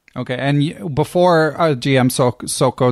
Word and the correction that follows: Soćko: did I say his name right? uh, Grandmaster Soćko. Soćko.